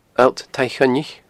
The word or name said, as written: Allt Taigh Choinnich